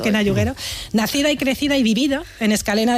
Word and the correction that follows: Escacena: del Campo, en Huelva. Escacena. Mira que te lo he dicho mal varias veces, ¿eh? Por ahí está, Escacena.